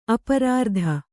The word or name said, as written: ಅಪರಾರ್ಧ